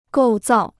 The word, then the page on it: 构造: gòu zào